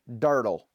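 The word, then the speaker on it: Durdle: It's D-U-R-D-L-E, Dirtle.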